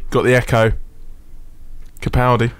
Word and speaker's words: Capaldi: But I heard him on another station intro his own song, and he's Capaldi. That's how he pronounces it.